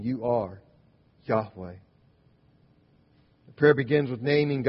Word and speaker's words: Yahweh: Yahweh, often mistranslated by us in English as Jehovah, but it's undoubtedly wasn't ever pronounced that way, probably Yahweh.